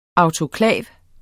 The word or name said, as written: autoklav